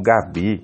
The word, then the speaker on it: G31: Você que vai procurar, o Gabi se escreve G31, né? Então a grafia, ela mistura letras e números, tá? É diferente da escrita comum de Gabi.